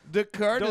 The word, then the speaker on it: Descartes: Descartes is on fire. – Don't try and deflate your own pretentiousness by pretending you don't know that's exactly how you say it.